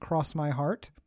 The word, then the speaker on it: X My Heart: It's written as X My Heart, but pronounced Cross My Heart.